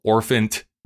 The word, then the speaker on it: orphant: It's actually originally called The Elf Child, but on its third printing, they would update it to be called Little Orphant Annie. And I'm saying that correctly, orphant, A-N-T, because it's supposed to be orphaned.